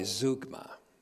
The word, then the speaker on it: zeugma: since we're dealing with Z-O-O sounds, zeugma, but it's spelled this way, zeugma, Z-E-U-G-M-A, but it's pronounced zeugma.